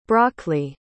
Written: brockley

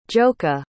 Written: joka

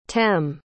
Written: tem

